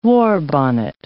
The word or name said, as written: warbonnet